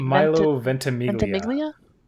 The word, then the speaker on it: Ventimiglia: Except I can never pronounce his name right. It's Milo. – Yeah, how do you say it? – Milo Ventimiglia.